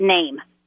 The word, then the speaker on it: Neim: Well, I couldn't trademark a common word like name, and so my dad suggested that I use the Latin spelling for the word name, N-E-I-M, but I pronounce it name, okay. Like a normal name.